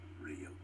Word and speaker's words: Ryuk: It's pronounced Ryuk.